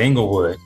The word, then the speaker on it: Inglewood: And you said it right, Inglewood.